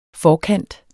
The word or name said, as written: forkant